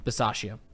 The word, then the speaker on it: Bisaccia: And I wonder if Derek Carr, you know, maybe had a really good relationship with Bisaccia. I don't know how to pronounce his name. Sorry. – Bisaccia.